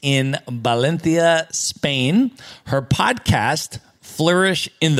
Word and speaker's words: Valencia: And I like that pronunciation, the Valencia. You stepped into that Castellano accent.